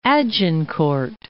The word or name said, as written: Agincourt